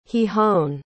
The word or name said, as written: gijón